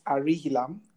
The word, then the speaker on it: Arihilam: So my social media details, you can find me using my name, Francis Arihilam. Francis spelled Francis Arihilam, A-R-I-H-I-L-A-M.